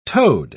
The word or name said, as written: toad